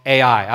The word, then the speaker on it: Ai: The next battle for Israel was a small and seemingly insignificant battle against a place called Ai. I've always loved that name because it's just two letters, Ai, and it's pronounced AI.